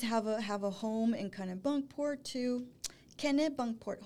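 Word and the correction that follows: Kennebunkport: Hold on. Ken, Kenny Bunkport. Like Kenny Bunkport.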